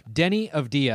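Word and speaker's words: Deni Avdija: Denny Avdia, I looked up the pronunciation, the Israeli basketball player.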